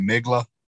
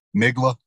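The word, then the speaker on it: Mgła: Yeah, even some of the newer ones, like Migla. I listen to them a lot for bench press. – What is it? – Migla. I think that's how you say it. MGLA.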